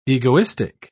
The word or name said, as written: egoistic